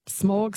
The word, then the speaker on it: Smaug: Apparently you say it like cow, so Smaug, but Smaug, Smaug.